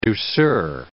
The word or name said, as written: douceur